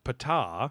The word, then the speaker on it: Ptah: Egyptian god Ptah, mm-hmm.